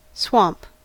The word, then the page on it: swamp: American English